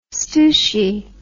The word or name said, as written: stoushie